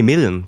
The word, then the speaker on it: Milne: Milne. Ja. Ähm, oder, nee, Millen wird er ausgesprochen.